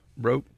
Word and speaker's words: Baroque: I've never heard of early, I can't pronounce it, Baroque periods, B-A-R. – Baroque.